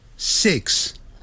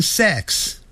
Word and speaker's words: six: 不过真正美国人在念这个时候他是念成没有声音的 five 要把 v 念出来的时候他停住了 five 但他不会念成 five 六 six it 短的元音 six 很多人会念成 sex 是性别那个性，好 sex 那是 sex，所以六啊要念成 six